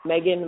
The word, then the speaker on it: Meghan: So It's just my name, at Meghan McPeak, if you're on Twitter.